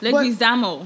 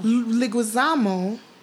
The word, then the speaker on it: Leguizamo: I'm not quite sure if I'm pronouncing his name 100% correct. – Leguizamo. – Leguizamo. I'm sorry, John.